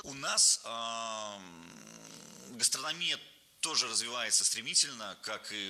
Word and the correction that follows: гастрономия: Не гастрономия, а гастрономия. Ударение в этом слове падает на третий слог.